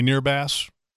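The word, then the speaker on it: Nehrbass: Andy Nearbass, which is like a way cool last name to have Bass in your last name.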